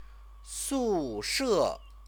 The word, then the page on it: sushe: Chinese